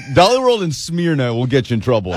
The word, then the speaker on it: Smyrna: Even though you pronounced it Smyrna, it's Smyrna.